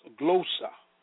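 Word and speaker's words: glossa (γλῶσσα): In the Greek word, tongues is called glossa, pronounced glossa, meaning a language, specifically one that is not usually acquired.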